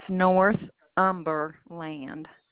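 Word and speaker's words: Northumberland: The one that you verified earlier, 12008 North Numberland Drive. – It's North Umberland. North UMBER.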